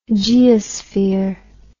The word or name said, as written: geosphere